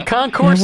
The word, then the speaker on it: Concours: Is the S silent? Is it Concours or Concourse? – No, Concours. We say is in the airport. – Okay. – The airport Concourse. – Okay. – Or you pronounce it Concours is a French word. – Oh, so these you do not pronounce the S.